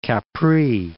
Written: Capri

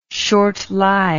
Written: short-lived

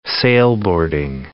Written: sailboarding

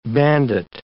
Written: bandit

